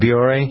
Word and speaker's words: Biore: So she went to, is it Biore? Is that how you pronounce it? B-I-O-R-E? Is it Biore? – Yeah, Biore. Mm-hmm.